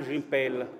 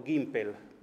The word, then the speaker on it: Gimpel: In Francia nel 1968 è stato pubblicato un altro libro, un pamphlet di Jean Gimpel, o Gimpel, a seconda di come lo si voglia pronunciare, è significativo che fosse stato pubblicato proprio nel 1968.